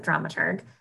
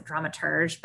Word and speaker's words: dramaturg: I pronounce it dramaturg. Even the definition of what a dramaturg is, there are many different interpretations, but I think that there is a right one. And I think it's dramaturg. There are a lot of people who spell it with the E and pronounce it dramaturge, but I think that's obnoxious.